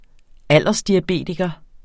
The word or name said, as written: aldersdiabetiker